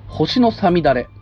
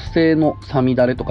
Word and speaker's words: 惑星のさみだれ: SF チックな独特の世界観、伏線を張り巡らされた巧みな構成の物語を多数手掛け、特に代表作星のさみだれ、これは惑星のさみだれ